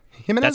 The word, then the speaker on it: Jimenez: Did I pronounce that right? Jimenez? – That's correct. Yeah, well, he pronounces it Jimenez.